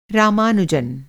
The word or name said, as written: रामानुजन्